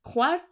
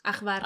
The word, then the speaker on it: Hvar: Que es una de las que vamos a hablar hoy, que antes, ¿cómo la llamábamos? – Ajvar. – Ajvar. – Pero porque se escribe H-U. – H-V. – No, H-V-A-R.